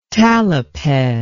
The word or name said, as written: taliped